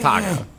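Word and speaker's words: Taako: His name is Taco.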